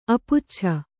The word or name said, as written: ಅಪುಚ್ಛ